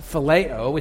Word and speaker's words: phileo: This is the brotherly love, pronounced phileo.